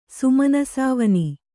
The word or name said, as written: ಸುಮನಸಾವನಿ